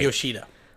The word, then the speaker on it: Yoshida: Right, Yoshida.